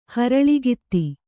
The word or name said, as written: ಹರಳಿಗಿತ್ತಿ